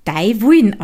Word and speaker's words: Dai Wuin: Ach ja, Dai Wuin bedeutet so viel wie deine Wolle und stellt den Versuch, den steirischen Dialekt aus meiner Heimat in Schrift zu fassen dar und wird Dai Wuin ausgesprochen. Also eben Dai Wuin heißt deine Wolle.